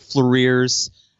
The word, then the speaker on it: Fleurir: Okay, well, the first song is Noir Fleurir's, I guess Fleurir would be the correct pronunciation.